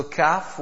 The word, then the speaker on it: kaf: Last week we had discussed the difference between the qaf and the kaf. So kaf was easy recited, free-flowing kaf as compared to qaf, and that would is pronounced with a full mouth.